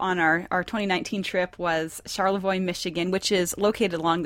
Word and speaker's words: Charlevoix: We were like saying Charlevoix, but yeah, it's Charlevoix. It's French.